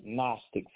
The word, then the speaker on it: Gnostic: When you say Gnostic, and if I were to tell some average lay person or novice to spell the word Gnostic for $1,000, by the way that I'm saying it, they would think that it would start with an N because of its sound. But the G is silent.